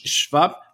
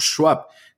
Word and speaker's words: Schwab: Segundo, el Foro Económico Mundial fue fundado en 1971 por Klaus Schwab, o Schwab, como pronuncian a la inglesa algunos, pero tardó años en contar con una relevancia internacional.